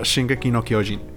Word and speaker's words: Shingeki no Kyojin: Ah, Shingeki no Kyojin.